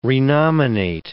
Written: renominate

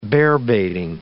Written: bearbaiting

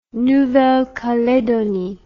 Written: nouvelle caledonie

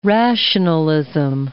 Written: rationalism

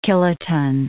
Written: kiloton

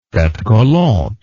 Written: fete galante

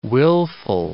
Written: willful

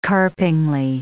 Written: carpingly

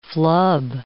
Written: flub

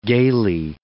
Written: gaily

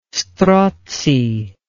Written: strozzi

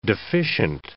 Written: deficient

